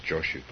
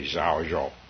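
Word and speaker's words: Zhaozhou: Japanese have called him Joshu for hundreds of years, and my teachers were Japanese, so I'm going to use Joshu, but it's actually Zhao Zhou, right? And if someone who was Chinese were here, they'd probably correct my pronunciation.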